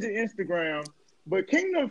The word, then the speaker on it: KNGDM: So it's Kingdom without the syllables. Kingdom without the syllables. Mm. You sound it out, still says Kingdom, though.